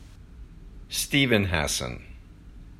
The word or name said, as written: Steven Hassan